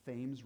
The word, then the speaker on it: Thames: the Thames River in Connecticut. I'm not mispronouncing that. That's how they pronounce it there, the Thames River.